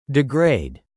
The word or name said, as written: degrade